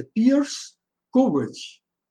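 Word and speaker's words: Piers Courage: Antes de, de tê-lo como piloto, que, segundo me disseram, uma, a pronúncia em inglês é Pierce Courage.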